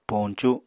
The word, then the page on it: poñcu: Apatani